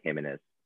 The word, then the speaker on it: Jimenez: You can say it with an H. Different countries pronounce it differently, but like the J can typically act as an H, so you'd say Jimenez.